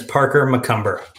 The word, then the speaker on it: Parker McCumber: Yeah, so my name is Parker McCumber.